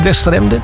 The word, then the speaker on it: Westeremden: Of zeg ik dat goed, Westeremden? – Ja, dat klopt helemaal. Boven in Groningen.